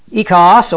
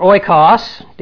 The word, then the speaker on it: oikos: Economia, actually, ekos or oikos, depending on Bible college pronunciation or modern Greek pronunciation, means house.